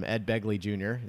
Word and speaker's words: Begley: I call it from Ed Begley Jr. Is that how you say his name? – Right.